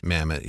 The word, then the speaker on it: Mamet: Mamet? – Mamet, yeah.